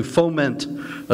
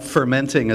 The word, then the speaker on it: foment: And one thing that I have learned over the last few months since it's been out is that very few people know how to pronounce foment. But, and you'd think, you know, radio hosts, and they're talking about fermenting a civil war.